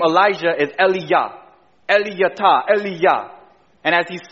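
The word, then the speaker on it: Eliyahu: Do you guys know how you say Elijah in Hebrew? Eliyah. Eliyah.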